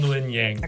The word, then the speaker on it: Luen Yang: Yeah, it's Jean Luen Yang.